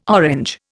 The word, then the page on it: Orange: Indian